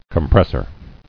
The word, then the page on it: Compressor: com·pres·sor